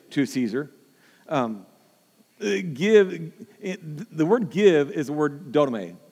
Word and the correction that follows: didomai: If I say that right, didomai, yeah.